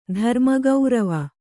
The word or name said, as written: ಧರ್ಮಗೌರವ